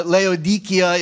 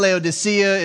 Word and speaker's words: Laodicea: And I just wanted to, Laodikia is the Greek pronunciation, Laodicea is the English pronunciation of it.